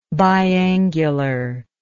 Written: biangular

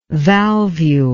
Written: valvule

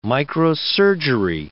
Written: microsurgery

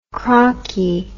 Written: crockie

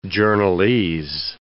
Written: journalese

